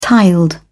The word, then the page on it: tiled: British English